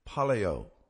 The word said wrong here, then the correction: Pelayo: Pelayo. – Palayo. – Pelayo.